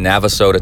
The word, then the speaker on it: Navasota: So it's the Brick House Triathlon in Navasota, Texas. N-A-V-A-S-O-T-A.